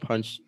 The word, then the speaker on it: Paczki: Hey, so yeah, tell us about Paczki.